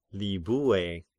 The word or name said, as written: Libye